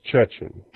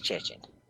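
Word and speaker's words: Cixin: Pronounced Chechen. – Yeah. According to the audiobook, it's pronounced Chechen. – They say their name backwards, so it's Lee Chechen.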